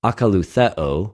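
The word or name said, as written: ἀκολουθεω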